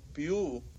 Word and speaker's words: Pew: ¿Cómo se pronuncia esto? ¿Pew Research? – Pew Research. – Pew.